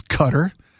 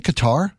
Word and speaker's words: Qatar: Can you please stop pronouncing Qatar as Cutter? It's insulting and grating. Hey, I'm trying. Lisa was telling me there was a New York Times that put an audio story about how the correct way to pronounce Qatar. And you know how you're supposed to have a guttural K sound from the back of your throat?